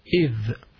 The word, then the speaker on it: إِذْ: Wa idh, alif, hamza under the alif, thal, idh, it's pronounced like that, idh.